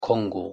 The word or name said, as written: Kongo